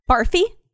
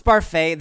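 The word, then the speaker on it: Barfée: Barfy? – It's pronounced Barfay. There's an accent AQ.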